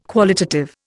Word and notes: qualitative: Indian

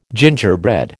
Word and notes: gingerbread: American English

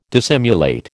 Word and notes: dissimulate: American English

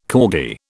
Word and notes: corgi: British English

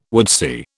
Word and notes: woodsy: British English

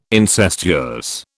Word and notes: incestuous: British English

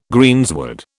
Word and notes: greensward: British English

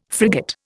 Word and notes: frigate: Indian

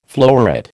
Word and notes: floweret: American English

